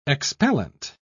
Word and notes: expellent: British English

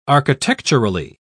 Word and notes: architecturally: British English

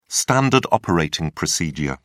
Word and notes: standard operating procedure: British English

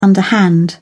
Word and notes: underhand: British English

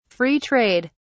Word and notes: Free trade: British English

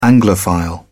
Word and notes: anglophile: British English

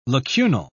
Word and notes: lacunal: British English

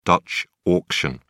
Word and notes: dutch auction: British English

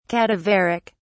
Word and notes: cadaveric: British English

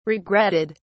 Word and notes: regretted: British English

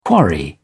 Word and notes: quarry: British English

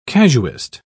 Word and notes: casuist: British English

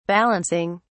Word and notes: balancing: British English

